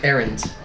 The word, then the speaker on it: Arrent: Errant. – Errant. Mm-hmm. How do you spell it? – A-R-R-E-N-T.